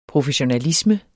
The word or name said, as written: professionalisme